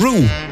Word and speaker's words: Rooue: Want neem nou die nieuwe die we afgelopen week ook wel draaiden van Roo. Want zo moet je het uitspreken. R-O-O-U-E, Roo.